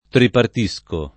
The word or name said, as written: tripartire